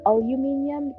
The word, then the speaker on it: aluminium: My mom would say aluminium, because that's how the British say it. And I'd be like, Mom, it's aluminum.